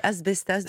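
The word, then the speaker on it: As bestas: Le premier, j'espère bien prononcer, Asbestas, c'est ça? – Oui, Asbestas de Rodrigo Sorogoyen.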